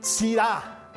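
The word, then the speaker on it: Sira (צרעה): Esta palabra hebrea que se pronuncia Sirah, a ver, Sira. Pero así. Ah. Sira. No como el vino Sira.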